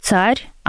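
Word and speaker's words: tsar: То есть в английском это будет «цар». Там даже «ц» как такового звука нет в английском, поэтому «цар». А во французском там «х» всегда «х» грассированное.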